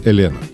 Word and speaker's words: Elena: За окошком события, которое повергло в уныние многомиллионную армию поклонников легендарного экипажа Себастьян Леб, Даниэль Элена. Кстати, многие в России произносят фамилию Даниэля как русское имя Елена.